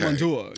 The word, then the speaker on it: Commandeur: So our set with Fred and Olga, how is it pronounced? – Olga Commandeur. – Commandeur, okay. – Commandeur, yes.